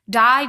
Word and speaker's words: Di: Die, die, die, die, die, die, die, die. Just to give you some context, it's spelled D-I, then like actual like die.